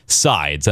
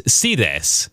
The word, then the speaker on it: Sides: So I said that. I was incorrect. I was the talk of the town for the high school's color guard team. – Oh. – They thought it was really funny that I mispronounced their instructor's name. – And it was sides.